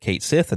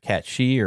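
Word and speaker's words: Cait Sith: Of course, you've got, you know, Kate Sith, and they did pronounce it Kate Sith instead of Kat She or whatever.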